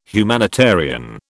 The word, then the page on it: humanitarian: British English